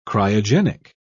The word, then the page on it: cryogenic: British English